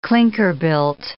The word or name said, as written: clinker-built